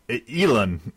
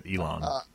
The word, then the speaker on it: Elon: Elon. Yeah, that's it. Elon.